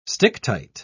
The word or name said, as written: sticktight